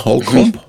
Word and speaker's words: Holcomb: Habe ich das richtig ausgesprochen? Cole Holcomb? – ja. Ich habe echt immer so meine, meine Probleme mit den Namen. Ähm, ist aber richtig.